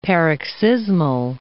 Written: paroxysmal

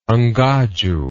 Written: Ngadju

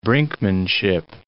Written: brinkmanship

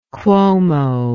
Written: Cuomo

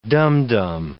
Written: dumdum

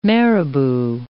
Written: marabou

